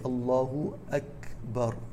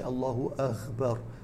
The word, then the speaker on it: Allahu Akbar: So how do you say it? You say Allahu Akbar with the kaf. You don't say Allahu Akbar with the ghain, as many people make that mistake.